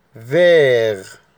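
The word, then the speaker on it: vert: لون رقم تلاتة فير، فير، التي لا تنطق، فير أو فير يعني أخضر.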